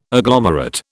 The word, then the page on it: agglomerate: British English